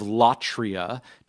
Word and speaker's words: latria: And latria, just how it sounds, it's spelled L A T R I A,